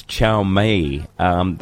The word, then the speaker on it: Xiaomi: That brand name you talk of is Xiaomi.